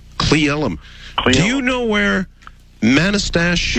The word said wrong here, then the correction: Manastash: Yeah, it's pronounced Manastash.